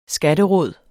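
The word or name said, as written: skatteråd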